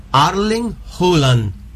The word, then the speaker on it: Erling Haaland: Para nosotros hasta ahora es Erling Haaland. – Sí. – A ver cómo se pronuncia. – A ver. – Erling Haaland. – Erling Haaland. Ahí está.